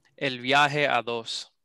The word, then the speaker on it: El Viaje a Dos: Let's hear you say it. – El Viaje a Dos. – Perfect, perfect.